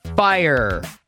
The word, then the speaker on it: fire: And Google says that you can either pronounce it and rhyme it with higher, so that way it is two syllables, or you can just pronounce it fire.